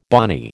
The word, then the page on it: bonny: American English